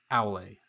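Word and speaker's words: Aulë: He's uh, the Valar of Smithcraft, and I was mispronouncing his name as Aul. It is Aule, so I will make sure to pronounce that correctly going forward.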